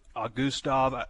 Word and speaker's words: Augustave: Yeah, it puts him at 12, and this is a guy that they really like, Isaiah Augustave, I guess is how you say it, A-U-G-U-S-T-A-V-E, Augustave, 6'2",